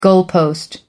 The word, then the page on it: goalpost: American English